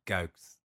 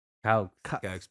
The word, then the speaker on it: Kuiks: Víctor Quick, sí, eso es como, eso es más como para ustedes. A ver, bien, bien, bien, ¿cómo se dice en holandés? ¿Cómo suena? – Kaux. Kaux.